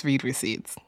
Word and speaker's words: read receipts: So today we're launching a new segment called Red Receipts. I want to see the receipts. Wait, yeah. No, it's it's read receipts. It's pronounced read receipts.